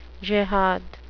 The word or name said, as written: jihad